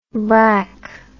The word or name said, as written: brak